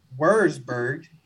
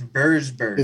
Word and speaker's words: Würzburg: And I told him that my mom's from Würzburg, using the W. And then he corrected and said, no, it's Würzburg.